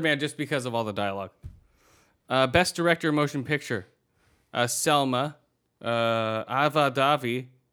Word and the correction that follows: DuVernay: Ava DuVernay.